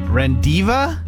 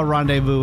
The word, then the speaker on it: rendezvous: R-E-N-D-E-Z-V-O-U-S. R-E-N-D-E-Z-V-O-U-S. – Rendiva? Divu? Rendezvous.